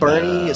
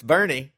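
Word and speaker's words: Boerne: Like Bernie is spelled Bowern, but Bernie. – But it's Bernie.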